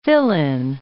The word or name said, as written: fill-in